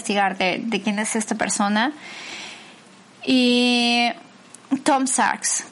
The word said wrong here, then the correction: Sachs: creo que es Tom Sarks. Tom Sachs, es S-A-C-H-S. Es como se escribe su nombre.